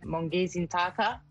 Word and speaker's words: Mongezi Ntaka: I'm saying that right. – Yes, that's correct. – Not perfectly, though. I can tell. – Yeah. So I'm not saying it. Now, the way how it's pronounced, it's Mongezi. I said Ntaka. – Mongezi Ntaka. – That's correct, yes.